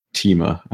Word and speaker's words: Timur: You can go ahead and take a look and discuss everything that goes on with Timur, as I found out that it's now been Timur, as it's pronounced, which I'm still not a fan of. I think Timur is a bit more epic.